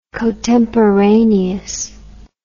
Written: cotemporaneous

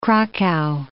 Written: Cracow

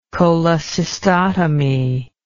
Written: cholecystotomy

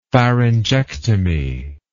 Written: pharyngectomy

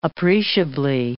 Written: appreciably

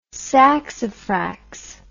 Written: saxifrax

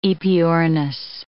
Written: aepyornis

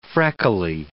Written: freckly

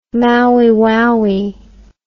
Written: maui waui

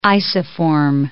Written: isoform

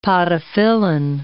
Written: podophyllin

